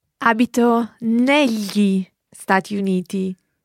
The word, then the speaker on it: negli: It's spelled N-E-G-L-I. So that G-L is the same GL that you hear in the word tagliatelle. So it's a very common sound in Italian, but in this word, it's pronounced negli, negli. It's like writing N-E-L-Y-I, if you like.